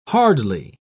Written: hardly

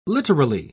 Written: literally

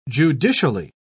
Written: judicially

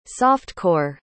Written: soft-core

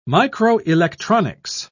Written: microelectronics

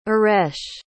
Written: iresh

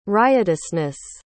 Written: riotousness

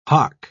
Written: hock

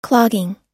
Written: clogging

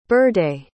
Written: berde

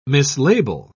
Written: mislabel

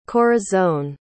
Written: corazon

